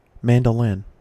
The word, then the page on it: mandolin: American English